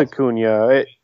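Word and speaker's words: Acuña: Yeah, it's Acuna. It's got that little, I'm going to sound like a complete moron, the little apostrophe thing above the N. So sure. – It changes how you pronounce it.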